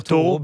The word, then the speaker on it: Thoreau: Réussi à le prononcer, mais oui, on a le droit de dire Thoreau.